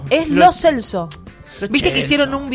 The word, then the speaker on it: Lo Celso: Cada uno tenía que decir cómo se pronunciaba su apellido. Y ahí Lo Celso dijo, che, se dice Lo Celso.